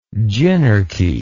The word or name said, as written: gynarchy